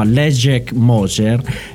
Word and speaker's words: Leszek Moser: In questo caso si incontra con un altro pianista, lui è un pianista polacco, si chiama Leszek Moser, lo so pronunciare perché è un amico Leszek,